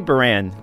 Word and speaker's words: Beran: So you pronounced your last name Beran? – I say Beran. – Beran? – Yeah. – Like French? Like Beran? – It was Beran when my dad came here.